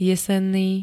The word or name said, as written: jesenný